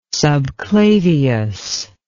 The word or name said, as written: subclavius